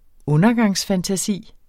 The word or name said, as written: undergangsfantasi